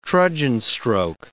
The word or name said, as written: trudgen stroke